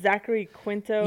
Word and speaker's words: Zachary Quinto: It's Zachary Quinto.